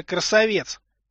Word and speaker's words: Красавец: Подпольная кличка Красавец. Ударение на ЕЦ.